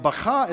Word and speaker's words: Baca: You like how I pronounce that? That's actually how you pronounce it. Baca. Okay, that's interesting. And Baca is a noun derived from a verb that means to weep.